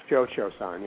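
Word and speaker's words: Cio-Cio-San: The illustrations in your book, for example, like Khoi Khoi San, I'm not sure if I'm pronouncing that correctly. – Cho Cho San, yeah. – Mm-hmm. Cho Cho, okay.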